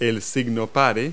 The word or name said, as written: stop sign